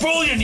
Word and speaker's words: bouillon: It's pronounced bullion, you idiot.